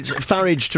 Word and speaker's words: Farage: Well, there was a lot of debate about this at one point, and he was asked, and he said he favours Farage. – I'll say Farage then. – Well, it's Farage to rhyme with garage, okay.